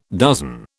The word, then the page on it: dozen: British English